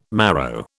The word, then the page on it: marrow: British English